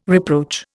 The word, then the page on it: reproach: Indian